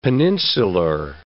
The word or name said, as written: peninsular